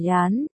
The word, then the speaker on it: Yán: Vì Nham đọc là Yán, nhưng lại là thứ đồ tồn tại trong kỷ niệm mấy năm của hai người.